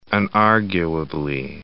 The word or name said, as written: unarguably